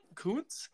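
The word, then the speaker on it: Koonce: Is that how you say that? Kuntz? – Yeah, that sounds right.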